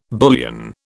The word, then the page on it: bullion: British English